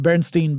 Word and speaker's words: Berenstain: The two that people tend to cite pretty frequently are the Berenstain Bears, yeah. – where it's spelled Stain at the end. That's the correct spelling, but everyone thinks it's Steen, S-T-E-I-N, instead mm-hmm. of S-T-A-I-N.